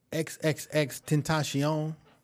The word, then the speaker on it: XXXTentacion: So on a more somber note, XXXTentacion was that's how you say it? – Yeah, was killed on Monday.